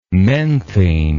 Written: menthene

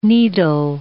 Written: needle

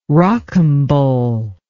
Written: rocambole

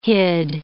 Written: hid

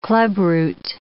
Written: clubroot

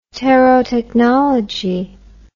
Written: terotechnology

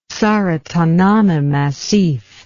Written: tsaratanana massif

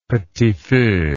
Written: petit feu

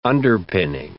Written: underpinning

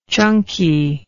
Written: chunkiness

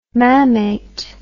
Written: mammate

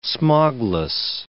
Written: smogless

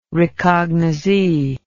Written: recognizee

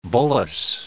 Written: bullous

